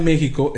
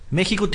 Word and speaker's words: México: México debe estar escrito con acento y pronunciar la X como J.